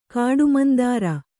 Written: ಕಾಡುಮಂದಾರ

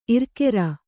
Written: ಇರ್ಕೆರ

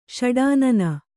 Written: ಷಡಾನನ

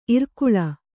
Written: ಇರಕಳಿ

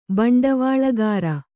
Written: ಭಂಡವಾಳುಗಾರ

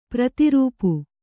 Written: ಪ್ರತಿರೂಪ

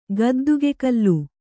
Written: ಗದ್ದಿಗೆಕಲ್ಲು

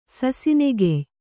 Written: ಸಸಿನೆಗೆಯ್

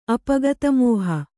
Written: ಅಪಗತಮೋಹ

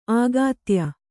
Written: ಆಗಾತ್ಯ

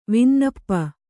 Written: ವಿನ್ನಪ

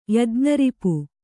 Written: ಯಜ್ಞರಿಪು

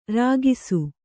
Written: ರಾಗಿಸು

